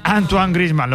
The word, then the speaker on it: Griezmann: Antoine Griezmann, lo pronuncian bien, la verdad.